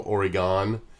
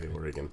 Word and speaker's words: Oregon: How to pronounce Oregon because they, you know, Oregon. He's like, no, it's like an Oreo and a gun, and I'm just looking at him going, Dad, that's not even close to how to say Oregon.